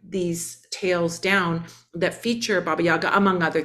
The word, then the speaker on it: Baba Yaga: And Baba Yaga is the Russian pronunciation. But what we find is in all the Slavic countries, there's a version of Baba Yaga. And it could be Baba Yaga, it could be Yedzi Baba, it could be there's all these different names, but they all have common characteristics.